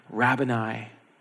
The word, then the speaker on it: Rabboni: And she calls out to him, Rabboni, which I always want to pronounce as Rabboni. It's like the Italian in me, like it's a pasta dish.